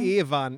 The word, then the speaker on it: Ewan: Ewan. Ewan. Genau. Ah, okay. McGregor, Entschuldigung, ich, ich, weiß, ich weiß es nicht. Ich nenne ihn immer Ivan. Ivan ist Russe. Ich nenne ihn e eh Ivan. Ivan schreibt man I-V-A-N und er ist Russe.